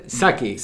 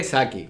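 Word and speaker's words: Psaki: ¿Cómo se dice? Saki. Saki. Saki. – Saki. – O sea, no, sé cómo. – Porque tú es sabes que P.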